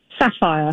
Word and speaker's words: Sapphire: and is it Safri? – Sapphire. – Sapphire. Of course, I couldn't pronounce it right.